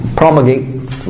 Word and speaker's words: promulgate: I just used promulgate, rhymes with perpetuate.